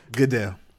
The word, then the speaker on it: Goodell: Goodell. – Goodell. Why keep saying a go? – Goodell. – Yeah. Goodell. Goodell. Like, go for Dell Computer. – G-O-O-D-E.